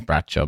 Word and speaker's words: Bracco: I think probably in Italian it is Braccio, but I think she says it Bracco, because like no Italian Americans can pronounce anything Italian.